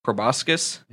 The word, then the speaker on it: proboscis: Vril lizards have a natural proboscis.